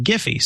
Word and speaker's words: Giphy: But you'll notice that I said Giphy because the creators of Giphy said we pronounce it Giphy.